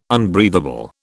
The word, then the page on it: unbreathable: British English